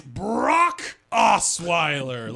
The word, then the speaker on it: Brock Osweiler: It's Brock Osweiler. like you say Brock Lesnar, the the UFC WWE star.